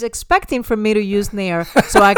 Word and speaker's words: Nair: Nair.